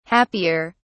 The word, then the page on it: happier: British English